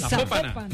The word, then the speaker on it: Zapopan: Es Zapopan.